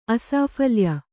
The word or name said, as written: ಅಸಾಫಲ್ಯ